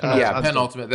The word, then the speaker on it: penultimate: I think he wrote and directed that last second, the pre-ultimate, or is it penultimate? How do you say that word? – Penultimate.